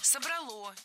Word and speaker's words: собрало: В отличие от язычества, от того, что было до христианства, именно христианство собрало наш народ воедино. – Собрало, неверно. А правильно, как вы, наверное, уже догадались, собрало. Опять же, убираем ударение с окончания, однозначно. Только собрало и никак иначе.